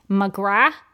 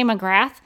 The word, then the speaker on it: McGrath: First of all, she wanted to remind everybody at the panel that her last name is pronounced McGrath, which I had heard her say before, but a lot of people just say McGrath.